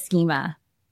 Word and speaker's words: schema: Sam says, get your SEO team on figuring out this schema.